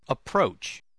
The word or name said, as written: approach